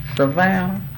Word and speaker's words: Savannah: Savannah.